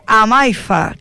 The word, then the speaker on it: Amaifa: Fundadora y presidenta de Amaifa. Creo que lo he dicho bien, ¿verdad? – Sí, Amaifa, como lo has dicho, tal cual.